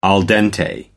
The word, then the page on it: al dente: American English